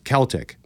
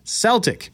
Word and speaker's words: Celtic: was over how to pronounce the word Celtic. I maintained that it should be pronounced Celtic. My friend maintained that it is pronounced Celtic, as in the name of the famed soccer club in Europe.